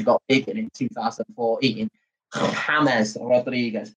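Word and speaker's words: James Rodríguez: James, James, James Rodriguez. That's how you pronounce his name, mate.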